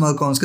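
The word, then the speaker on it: Malkauns: Yeah, I think it's pronounced Malkaos because it's like a rag.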